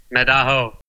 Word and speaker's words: Medaho: When I heard for the first time your album, is it Medaho? Is it right? Am I pronouncing right? – Medaho. – Yeah, Medaho. Yeah.